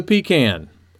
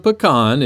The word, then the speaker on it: pecan: My favorite nut is the pecan, or the pecan if you prefer.